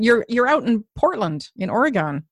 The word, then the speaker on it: Oregon: So they say Oregon. – Oregon, okay.